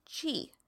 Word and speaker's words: qi: You've seen it written as qi, okay? So that is pronounced qi.